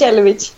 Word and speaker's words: Jelovic: Och så nu ska jag uttala efternamnet. Jag har alltid fel på vad jag ska lägga betoningen. Jelovic, okay. Vill jag säga. – Alltså fantastiskt. Första rycket gick jättebra. – Det var det alltså. – Ja, det är precis så man säger det, Jelovic.